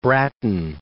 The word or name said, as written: Brattain